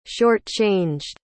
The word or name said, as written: shortchanged